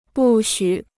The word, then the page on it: 不许: bù xǔ